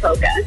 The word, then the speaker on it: polka: Hey, so do you pronounce the L in polka? – Oh, heck no.